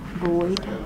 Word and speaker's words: Boyd: Boyd. – B-O-Y-D? – Mm-hmm. – And it's, how do you spell Kathy? – K-A-T-H-I.